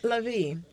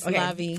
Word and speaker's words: Lavie: So, Lavie. – Yes. – I'm saying it right, right? – Yes, Lavie.